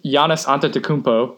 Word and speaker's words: Giannis Antetokounmpo: So – for the East, Giannis Antetokounmpo was the leading vote getter.